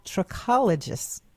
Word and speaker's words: trichologist: She is a certified trichologist. Am I saying that correctly? – That is correct.